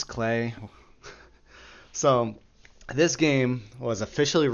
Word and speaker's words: Klei: It's an indie survival game that was developed by a Canadian company named Clay Entertainment, and as much as it pains me, it is pronounced Clay, and I always want to pronounce it Cly, but because it's spelled K-L-E-I. But from what I found, it's pronounced Clay.